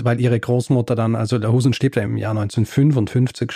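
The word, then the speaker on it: Lahousen: Tatsächlich war das nicht Erwin Lahausen, wie ich ihn ausgesprochen habe, sondern Erwin Lahusen.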